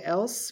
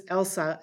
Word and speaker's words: Else: So they say Else because it's spelled like E-L-S-E. So I just always say, oh, it's Elsa, as in Frozen.